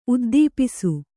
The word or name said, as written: ಉದ್ದೀಪಿಸು